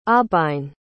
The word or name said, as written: aubine